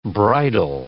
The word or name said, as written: bridle